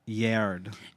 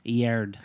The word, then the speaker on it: Yared: The official pronunciation, Yared. – Yared. – And I messaged her. – Yes. – And said, I love your song. I featured it on our podcast. Next time we're in LA, would love to see you. Maybe we can interview. And she messaged me back and said, that would be amazing. And she posted the screen recording of us talking. – Yes. – Where I embarrassingly described her name as the pronunciation of GERD, like the disease.